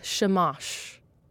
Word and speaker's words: shamash: I had to ask for the correct pronunciation of this. It's called a shamash.